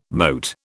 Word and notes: moat: British English

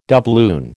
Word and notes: doubloon: American English

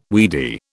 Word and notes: weedy: British English

